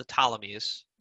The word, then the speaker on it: Ptolemies: So the problem is that the ruling class in Egypt, the Ptolemies, spelled with a P, the Ptolemies are siding with Caesar on this one.